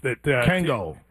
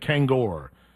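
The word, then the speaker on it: Kangol: Kango. Yeah, what? – Did I say it wrong? – Kango hats. You said Kangor.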